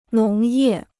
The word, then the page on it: 农业: nóng yè